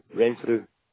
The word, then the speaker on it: Renfrew: No, Renfrew. Renfrew. – Ren. You'll have to spell that for me, if you don't mind. – R-E-N. – Yeah. – F-R-E-W.